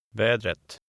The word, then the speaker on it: vädret: The final T is pronounced. – Vädret.